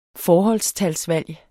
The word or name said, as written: forholdstalsvalg